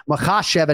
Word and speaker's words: Makhachev: But ultimately, we're trying to hit Makhachev and hit that second syllable.